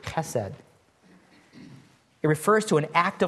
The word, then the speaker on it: chesed: The word kindness here is a Hebrew word, chesed. Chesed is how it's pronounced.